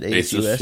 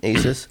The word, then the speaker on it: Asus: I ended up buying this 13-inch Asus, or Asus, or however you pronounce it, Asus. Yeah. Asus.